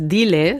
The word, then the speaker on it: Diles: Dimash heißt das Stück. Habe ich es richtig ausgesprochen? – Nee, hast du falsch. Nein, falsch. Das heißt Diles.